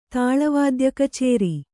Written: ತಾಳವಾದ್ಯಕಚೇರಿ

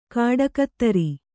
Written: ಖಡುಗಧಾರೆ

